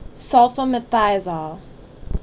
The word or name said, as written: Sulfamethizole